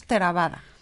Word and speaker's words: Theravada: Dentro del budismo, Theravada. Theravada. Theravada. Que lo diga ella. Pronunciamos Theravada. Ok.